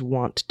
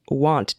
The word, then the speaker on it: wont: I pronounce it want, I pronounce W-O-N-T, want, instead of won't. I am wont to do. I pronounce it want, which really doesn't make sense grammatically. Let's bring back won't, I guess, is I think what Major Headache is saying, that they prefer it be pronounced won't.